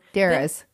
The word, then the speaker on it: Deras: Daras, D-E-R-A-S, by phone. – Daras.